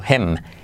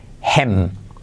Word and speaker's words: him: Por ejemplo, him, Hem. Esa i se hace como una e. No se dice him, sino hem. Habla con él, frase de ejemplo, talk to him.